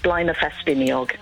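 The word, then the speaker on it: Blaenau Ffestiniog: So a nice little town called Blaenafestiniog.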